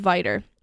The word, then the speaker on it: Vidor: and not far from Vidor, which I heard is how you pronounce it. – Yes.